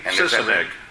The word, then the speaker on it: Sisenig: Because I've been calling it Sisenig. – Sisenig. – That is right? – Sisenig, yeah. – Okay. – Which is Genesis, spelled backwards, right? – Oh, you clever writer.